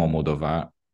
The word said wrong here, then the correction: Almodóvar: falei errado de novo, mas é isso, o Almodóvar,